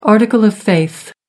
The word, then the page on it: article of faith: American English